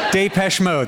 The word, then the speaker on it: Depeche Mode: Depeche Mode. – That's how you say that. – Yeah. Depeche Mode? – Depeche Mode. – Was it them or someone like that? – I think it was Depeche Mode.